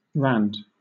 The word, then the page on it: rand: British English